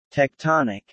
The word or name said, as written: Tectonic